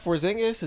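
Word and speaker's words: Porziņģis: That he has Kristaps Porzingis.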